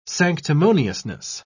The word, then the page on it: sanctimoniousness: British English